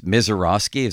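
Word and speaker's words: Misiorowski: Let's do our first item on that, is that pitching matchup to begin the second half, because this Mizorowski, is that how you say his name? – Yes.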